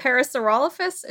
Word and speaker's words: Parasaurolophus: Parasaurolophus is the second one and Quetzalcoatlus is the third one.